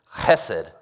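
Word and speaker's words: chesed (חֶסֶד): The word in Hebrew is chesed. I want you all to say that with me and really pronounce the ch in the back of your throat. Ready, everyone?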